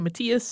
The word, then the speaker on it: Matthias: This religious network eventually led Isabella to a man named Robert Matthews, who had taken the name Father Matthias, which is spelled Matthias. Some people might pronounce it that way. Most Germanic languages say Matthias, so we're going with that.